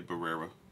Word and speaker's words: Barrera: Is that Barrera? Am I saying this right? – Barrera. I think all three. Yeah, Barrera. I think all three of them.